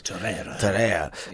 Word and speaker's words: Torreira: I think the third goal, he does Torreira. – Yeah. – I'm probably mispronouncing that like I do all the names. – Torreira.